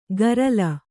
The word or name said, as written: ಗರಲ